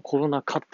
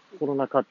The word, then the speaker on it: コロナ禍: まあ、これ、かって読むんだろうなって。ねえ、まあ、大概の大人だったらこれはかって読むんだろうと思いますよね。はい。で、私もコロナ禍って読んでましたけど、本当にコロナ禍っていうのかどうかっていうのがずっと分かってなくてですね。